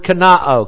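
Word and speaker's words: kenoō: And this is the Greek word kenao, K-E-N-O, long O, kenao.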